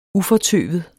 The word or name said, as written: ufortøvet